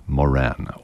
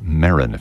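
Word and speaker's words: Moeran: one of the songs of springtime by that same composer, Ernest J. Moran, or Marin, if you prefer. I've heard it pronounced both ways.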